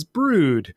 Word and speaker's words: Broood: and I now know that it is correctly pronounced as Brood, like rhymes with good. – Or food. Or food.